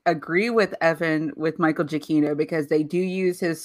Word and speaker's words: Giacchino: I hope I said that right. – You did. – You didn't, but okay. – I did. – No, you did. It's Michael Giacchino. Oh. Yeah, he did say it right.